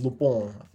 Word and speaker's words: Lupin: Is it Lupin? – Lupin. – Is that how you say it? – Yes, Lupin.